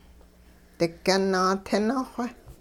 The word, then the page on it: digyun naatini-xw: hupa